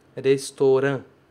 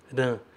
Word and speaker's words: restaurant: Restaurant. Não é nasalizado. Ran. É restaurant. Restaurant. Ra-n. Ra-n. Ra aberto e depois fecha com um.